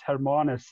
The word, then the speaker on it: Hermanus: A lot of people pronounce it wrong. There was an American company doing a drone flyover of countries, but they get all the pronunciations wrong, but that's understandable, different cultures. But okay. It's Hermanus. That's the way you pronounce it.